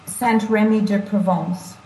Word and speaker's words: Saint-Rémy-de-Provence: Wait, pronounce the town you're in again? – It's Saint-Rémy-de-Provence.